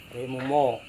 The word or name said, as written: remumo